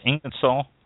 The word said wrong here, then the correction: Isinglass: Yes. Isinglass.